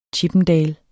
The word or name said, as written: chippendale